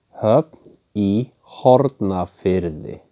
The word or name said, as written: Höfn í Hornafirði